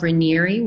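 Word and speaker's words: Raniere: So when it used to be spelled Rainieri with like an I at the end, but it was always pronounced Rainier.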